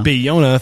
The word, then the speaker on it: Bayona: Did I say that correctly? – Bayona.